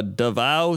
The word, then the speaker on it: Davao: We have a weather request for uh, Davao City, Philippines, pronounced like the vow.